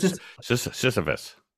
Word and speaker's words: Sisyphus: Sisyphus.